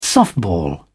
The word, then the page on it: softball: British English